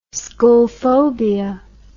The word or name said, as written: schoolphobia